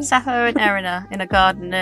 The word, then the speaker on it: Sappho: but I'm pretty sure it's Sappho and Erinna.